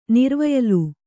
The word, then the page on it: ನಿರ್ವಯಲು: nirvayalu